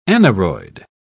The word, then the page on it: aneroid: British English